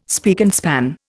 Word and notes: spick and span: Indian

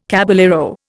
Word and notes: caballero: Indian

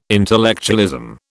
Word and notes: intellectualism: British English

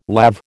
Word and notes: lav: American English

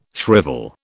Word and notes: shrivel: British English

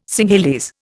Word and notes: Singhalese: Indian

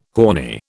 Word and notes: horny: British English

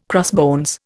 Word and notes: crossbones: Indian

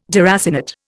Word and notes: deracinate: Indian

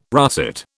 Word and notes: russet: British English

